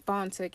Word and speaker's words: Fanta: But my name on Facebook is Fanta Canty, and it's spelled F-A-N-T-A, and my last name is K-A-N-T-E.